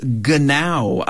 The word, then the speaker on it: Genau: They are called Genau, I believe it is pronounced. It is a German word, the internet tells me.